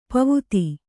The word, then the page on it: ಪಾವತಿ: pāvati